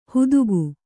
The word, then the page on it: ಹುದುಗು: hudugu